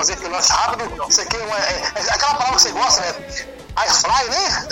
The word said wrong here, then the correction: air fryer: É fryer. Você pronuncia bonitinho. Fala de novo. – Air fryer.